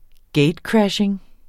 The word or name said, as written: gatecrashing